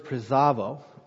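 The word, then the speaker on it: presavo (πρεσβεύω): The Greek word is presavo, believe it or not, that's how you pronounce it. Bees are V's, and U's are sometimes sounded like B's.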